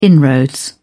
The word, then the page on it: inroad: British English